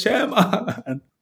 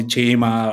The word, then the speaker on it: Chema: And I was like, my friend told him like, hey, it's not Chema, it's Chema. It's something different how we pronounce and how in a country like this words are pronounced, that I just wanted to make it clearer, if that makes mm-hmm.